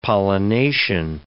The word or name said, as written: pollination